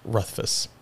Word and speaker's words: Rothfuss: Rothfuss.